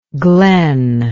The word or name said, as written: glenlike